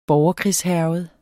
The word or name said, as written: borgerkrigshærget